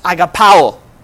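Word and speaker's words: agapao (ἀγαπάω): And love, according to the context of this verse that we just saw, comes from a Greek word, and it's pronounced agapao, which is a kind of love where you put others before yourself.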